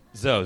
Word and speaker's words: Zo: Zo.